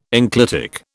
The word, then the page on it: enclitic: British English